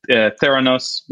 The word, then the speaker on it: Theranos: Theranos, am I pronouncing it yet? – Yeah.